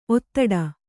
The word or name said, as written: ಒತ್ತಡೆ